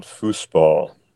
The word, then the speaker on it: Fußball: So I believe they call it Fußball. – Yes. Yes, that is true. That is the correct, or pronunciation, but regardless, we have listeners who are across the pond from us.